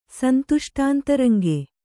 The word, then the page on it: ಸಂತುಷ್ಟಾಂತರಂಗ: suntuṣṭāntantaranga